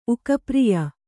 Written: ಉಕಪ್ರಿಯ

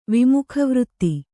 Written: ವಿಮುಖವೃತ್ತಿ

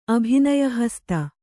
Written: ಅಭಿನಯಹಸ್ತ

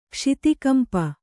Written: ಕ್ಷಿತಿಕಂಪ

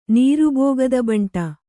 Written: ನೀರುಬೋಗದಬಂಟ

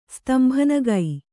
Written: ಸ್ತಂಭನಗೈ

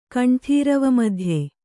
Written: ಕಂಠೀರವಮಧ್ಯೆ

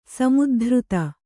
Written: ಸಮುದ್ಧತ

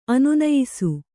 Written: ಅನುನಯಿಸು

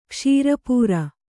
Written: ಕ್ಷುರಪ್ರ

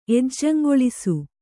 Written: ಎಜ್ಜಂಗೊಳಿಸು

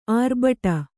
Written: ಆರ್ಭಟ

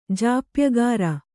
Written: ಜಾಪ್ಯಗಾರ

